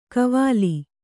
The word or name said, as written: ಕೈವೊಲ